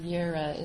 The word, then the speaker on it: Jireh: That's the name of Jehovah that's called Jehovah Jireh, or Yireh is how you pronounce it in Hebrew.